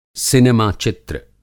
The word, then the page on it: सिनेमा चित्र: Hindi